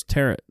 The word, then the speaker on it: tarot: What was tarot? Like a carrot? – It's like with a T. – It's like ballet where you drop the T. – Yeah. – Tarot. – The silent T.